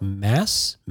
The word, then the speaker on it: Mads: But I guess consistent with the time travel notions, the Nazi character played by Mads Mikkelsen, which I learned you don't actually pronounce it Mads in his native tongue. I don't remember how. I think it's more like Mass Mikkelsen, but nobody says that.